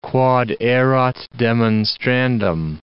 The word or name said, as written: quod erat demonstrandum